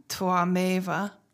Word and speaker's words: Tvameva: And it's kind of it's spelled T-V-A-M-E-V-A, but I believe in Sanskrit it's usually pronounced with a more of a W sound or a combination, like Twameva.